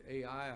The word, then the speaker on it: Ai: The next area that they were supposed to conquer was, I've always pronounced it AI. I heard a fellow on the radio yesterday pronounce it I, but it is spelled AI, so I suppose either one could be correct.